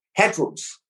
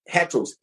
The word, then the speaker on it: heteros: It's spelled heteros. Heteros, H-E-T-E-R-O-S, heteros.